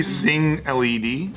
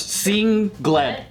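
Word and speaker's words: Sengled: It might be Sing LED. – No, it's Sing Gled. Oh, yeah, that's how they say pronounce it like.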